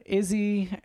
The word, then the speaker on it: Izzy: how do you say Izzy? – That's right.